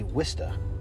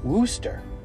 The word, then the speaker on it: Worcester: I've had people, when I was a kid, we used to say Wista, like W-I-S-T-A, Wista, Wista. And some people still do. And then somewhere along the line, we just changed it to Worcester. And now there's this push of people calling it Wooster.